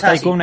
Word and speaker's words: Tycoon: Cuando dices Tycoon, es lo que se escribe Ticon. – Ahí está, Ticon, ah, vale, vale. Tycoon, sí.